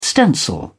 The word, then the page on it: stencil: British English